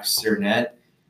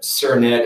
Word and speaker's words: Sernett: So it's I am Jack Sernett. My name's in you know all the podcast stuff, but Sernett is spelled S E R N E T T.